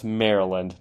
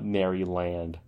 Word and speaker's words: Maryland: It's pronounced Maryland, not Maryland.